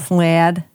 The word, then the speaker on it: Slaad: Slad? – Slad. – Red slad. – You got to say it like that.